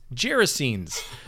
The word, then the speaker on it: Gerasenes: One is we learned how to pronounce gerasenes. – so if you didn't know, that's how it is. It's not a hard G, it's a soft G.